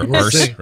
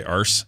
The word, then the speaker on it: arse: That's how you say it down there. Arse, right? You say arse. Anyway, arse. So why don't you. – We spell it A-A-S-S, and we spell it A-R-S-E. – A-R-S-E.